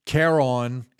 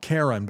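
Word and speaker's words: Karoun Demirjian: Now, according to the New York Times, and this is, I'm going to mispronounce this name, I'm sure, but it's Caron, it's K-A-R-O-U-N, not Karen, but Caron Demirjian, D-E-M-I-R-J-I-A-N.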